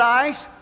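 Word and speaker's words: ace (εἰς): The word for in that text is translation of the Greek term eis, pronounced ace, or some pronounce it ice, and is defined by there again as in order to obtain.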